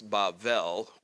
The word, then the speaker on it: Bavel: I know that in Hebrew it's Bavel with a V sound, but it has, when you put it in different forms, it has this repetitious sound to it.